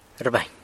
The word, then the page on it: rbany: sanlucasquiavini